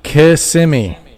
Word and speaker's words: Kissimmee: Actually, I don't know how to say it, but can you tell me how to say the K word? – What are you talking about? – Kissimmee. – You said it right. Kissimmee.